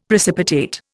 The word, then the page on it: precipitate: Indian